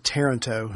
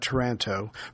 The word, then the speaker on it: Taranto: Yes, I just found out it's pronounced Taranto, not Taranto, from a listener who is married to someone.